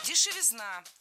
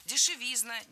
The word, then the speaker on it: дешевизна: Дешевизна – тоже неверно. В этом слове, опять же, ударным должен быть суффикс «изн». Дешевизна, дешевизне.